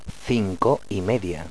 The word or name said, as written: Cinco